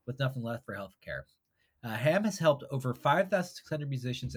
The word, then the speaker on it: HAAM: The cause this week is HAM, H-A-A-M, pronounced like the pork product.